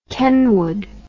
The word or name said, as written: Kenwood